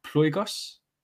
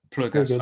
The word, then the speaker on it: Ploigos: One thing that I really wanted to talk about as well, as we come to the end of our conversation, is, and tell me if I've butchered the name of this, but Ploygos? – Yep. – Ploygos, okay. So your side project, right?